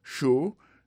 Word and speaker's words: sure: Show, S-U-R-E, décrit sur, mais elle prononce show.